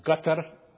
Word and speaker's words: Qatar: And they're acting as if their effort would help those people in Qatar, or Qatar, as it is properly pronounced, or in Australia, or wherever they're playing.